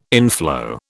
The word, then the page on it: inflow: British English